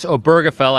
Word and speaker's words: Obergefell: And it's Obergefell. I just learned that myself, and I've been saying it wrong for weeks now,